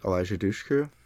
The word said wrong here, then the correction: Eliza Dushku: Okay, I thought I heard you wrong. – Elisa Dushku. – Eliza. – Eliza.